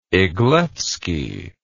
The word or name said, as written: Eglevsky